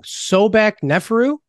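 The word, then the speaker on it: Sobekneferu: And it's about, now correct me if I'm pronouncing this wrong, Sobek Neferu? – That'll do. – Is that the okay? Yeah. That'll do. Perfect.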